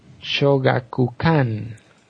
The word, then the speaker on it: Shogakukan: es una propiedad de Shueisha y Shogukawa. Bueno, se pronuncia Shogakukan. Casi lo mismo.